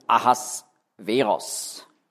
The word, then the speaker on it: Ahasveros: Also in der Bibel, hebräisch, wird sein Name ausgesprochen Ahasveros. Xerxes der Erste,